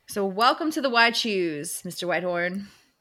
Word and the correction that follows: Whitethorn: Yeah, Whitethorn. – Thorn? Jesus.